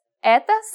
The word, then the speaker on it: Это: We read this Это собака. Remember how O can be pronounced as a soft A? In this case, we pronounce both O's as A.